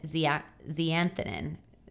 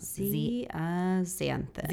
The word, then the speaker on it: zeaxanthin: And two of the powerful antioxidants that have shown to be very supported in literature is lutein and zeaxanthin. Is that right? I always say that one wrong. – Zeaxanthin. – Zeaxanthin. Yeah, oh, yeah.